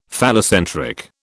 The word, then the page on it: phallocentric: British English